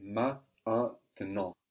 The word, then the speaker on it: maintenant: But in maintenant, MA is considered a syllable, IN is considered a separate syllable, so we get maintenant.